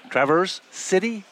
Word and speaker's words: Traverse City: The next time you hear from us, we'll be in Traverse City.